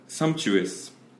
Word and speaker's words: sumptuous: Okay, 그 T 거의 CH처럼 해도 될 것 같아. Sumptuous. – Sumptuous. – 네, 그거 예, 괜찮은 것 같아. CH라고 생각해도.